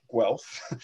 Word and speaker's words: Guelph: We're very interested in sports performance, and that was part of my job when I was hired to come to Guelph.